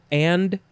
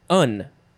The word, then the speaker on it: and: It's the names, capital D, A-R-B-Y, Darby, and Joan, capital J-O-A-N. And what I find interesting is the pronunciation guide says that the and word is just un, or an. There's no D sound in there, and.